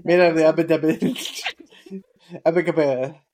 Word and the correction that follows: abaca: I think it's abaca. – Pronounce like an abacus. – What's an abacus? – The old timey calculator thing that we used to have. Abaca. Abaca. Is that what you said? – Yeah.